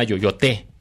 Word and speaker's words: Yoyoté: Este proyecto se llama Yoyoté. Así con acento al final, y menciona que, disculpe usted mi francés, pero proviene del verbo que se llama, del verbo perder la cabeza, yoyoter.